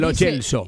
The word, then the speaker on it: Lo Celso: Toda la vida le dijimos Lo Celso. – Y que se pronuncia Lo Celso. – Y él dice Lo Celso.